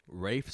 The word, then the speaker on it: Rafe: Rafe Spall. I think that's how you say his name. Right? Say his I name. Don't see a face. – It's not Rafe, so it's Rafe.